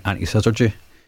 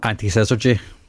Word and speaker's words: antisyzygy: literary circles called the Caledonian antisyzygy. And it's the Caledonian. Sorry, sorry, the Caledonian what? Antisyzygy. Antisyzygy. Yep, that's exactly it.